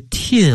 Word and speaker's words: teen: 哎，我 14 她 13，但是大家发音一定要发准了，这个 teen 啊一定要准。我发现很多同学这个 teen 这个音呢、啊会念成这个 T 啊，I'm 40, she's 30 啊，这班级两个超龄大学生，一个 40多岁，一个 30多岁哈、啊，两个初中生哈、啊。